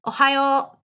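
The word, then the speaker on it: ohayō: Ohayo.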